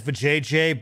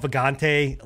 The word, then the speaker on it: Vagante: Along with, we pronounce it as Vajayjay, but it, you know, it's called Vagante, like, or it's pronounced, or it's spelled Vagante something.